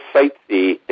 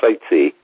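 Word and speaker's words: Sitzai: His name is Bill Sightsee. And it's like if you go sightseeing, that's how you pronounce it, but it's spelled pretty strange. It's spelled S-I-T-Z-A-I. S-I-T-Z-A-I, but it's pronounced sightsee.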